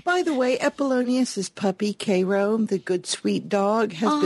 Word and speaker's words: Cairo: It is, and I purposefully go with the South Georgia pronunciation, Cairo. It's Cairo. – I fully approve of Cairo. C-A-I-R-O.